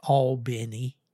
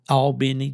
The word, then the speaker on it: Albany: The locals pronounce that Albany. But Albany? – Albany, Georgia, yeah.